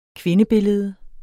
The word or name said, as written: kvindebillede